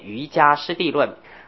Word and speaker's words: 瑜伽师地论: 其实迦跟伽都对，原因是古汉字我们现在念人在迦，这个在古汉音里念是瑜伽地论，在梵本那样念叫 Yogacara，他不念呢瑜伽，它的发音是 yoga yogacara，所以在唐音里面茄子破音字念瑜伽师地论。